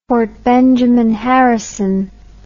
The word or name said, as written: Fort Benjamin Harrison